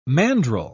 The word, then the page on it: mandrel: British English